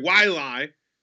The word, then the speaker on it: Waialae: But anyway, Waialae Let's try to get that right the rest of the game, the rest of the week.